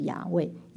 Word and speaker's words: 亚伟: 但其实希伯来原文是不能发音的四个子音 YHWH，但是加上母音的时候就叫亚伟，神的名其实就是亚伟，亚伟，亚伟。